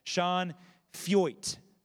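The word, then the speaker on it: Sean Feucht: Sean Feuit.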